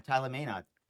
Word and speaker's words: Tyler Menard: Hey, so guys, thanks so much for coming on, Tyler Menard.